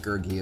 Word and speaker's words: Gurgi: It's pronounced Gurgi as a heads up.